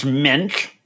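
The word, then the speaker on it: Moench: Actually, his name is pronounced Munch. – Yeah, thank you. Is it really Minch? Are you for real? – I don't think so.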